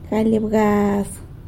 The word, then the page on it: galy abgaz: sanlucasquiavini